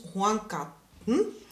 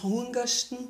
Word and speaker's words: Horngatten: Perfekt ausgesprochen. Zumal es in jeder Region in Tirol dieses Wort in anderer Aussprache gibt. Also in manchen Regionen ist es also im Tiroler Unterland eher das Horngasten. Oder Horngasten und im Oberland ist es das Horngarten. So hat jede Region eine eigene Aussprache und es heißt nichts anderes als plaudern.